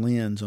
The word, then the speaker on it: Lenz: Well, we've got Dawson Lenz on here.